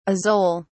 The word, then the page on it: azole: British English